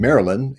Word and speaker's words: Marilyn: Marilyn, M-A-R-I-L-Y-N, and second name or last name, A-L-A-U-R-I-A.